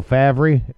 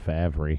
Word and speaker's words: Favre: F-A-V-R-E. I moved up here, and I'm like, oh, Favre? It's Favre. It's spelled Favre. – It kind of is. – It is. But it's F-A-V-R-E. Favre.